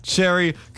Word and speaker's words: Chery: You said it right. – I did say it right. I said Chery correctly.